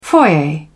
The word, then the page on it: foyer: British English